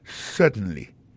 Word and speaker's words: certainly: certainly alors en parlant de accent gardez bien pour un mot certainly accent tombé sur premier syllabe là yes certainly témoin point pour encore yes certainly comprenez accent tombé sous premier syllabe là.